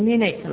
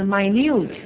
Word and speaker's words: minute: 倒数第二个单词啊，它作为形容词的时候是微小的，而且它的读音也变了，不是读成 minute 了。大家都熟的是倒数第三个单词哈，倒数第二个单词 minute，微小的啊。